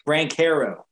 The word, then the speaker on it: Banchero: Banchero. – Brancaro, that's what it is.